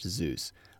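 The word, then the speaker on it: Xeus: I found it helpful to build examples using Zeus Kling, and that's X-E-U-S, I assume that's pronounced Zeus, and a link to the GitHub QuantStack Zeus Kling page, which is IPython but for C++.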